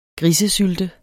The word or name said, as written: grisesylte